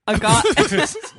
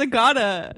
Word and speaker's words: Agata: You pronounced my name wrong, I gotta say. – Oh, thank you for checking him. – Is it Monica? – It's Agata. It's Agata.